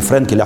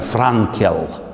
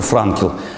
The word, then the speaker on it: Франкл: Прошу прощения, меня слушающие, э, э очень внимательно слушающие э, друзья поправили и говорят, в русском языке его фамилия произносится по-другому, не Френкель, а Франкел – вот Виктор Франкл,